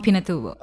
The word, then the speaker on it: Pinatubo: Mount Pinatubo.